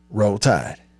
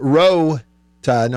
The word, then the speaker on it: Roll Tide: Now, I know a lot of them who they don't pronounce the L's. They just say Row. Row Tide. Row Tide.